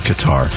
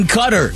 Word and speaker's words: Qatar: She's like, no, you mean Qatar. I'm like, no, I really don't. I mean Qatar. That's how it's pronounced.